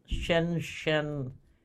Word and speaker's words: Shenzhen: And just as a reminder, PAX's corporate headquarters are in Shenzhen, China. I think that's how you pronounce it. – I think you did perfectly there.